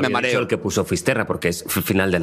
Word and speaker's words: Fisterra: Entonces, llegó a Finisterre. ¿Se dice así? – Fisterra. – Es como se pone los gallegos también, en fin.